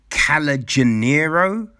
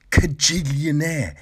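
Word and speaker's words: Kajillionaire: I think I was, in my head, it was like Calajanero, right? Calajanero, like Calabazoo. And then the film starts, and then suddenly it came to me, Cajillionaire. It's called Cajillionaire. That's how you pronounce that friggin' word.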